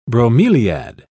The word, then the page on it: bromeliad: British English